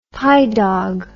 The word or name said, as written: pi dog